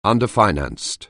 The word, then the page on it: underfinanced: anglais